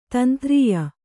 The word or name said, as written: ಧೀಂದ್ರಿಯ